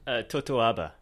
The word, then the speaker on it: totoaba: There were two endangered species being protected on this operation, the Totoba fish and the Vaquita porpoise. Am I saying that right? – Totoaba. – Totoaba, that sounds much nicer.